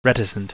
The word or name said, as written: reticent